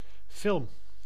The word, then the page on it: movie: /ˈmuː.vi/; [ˈmuː.vi]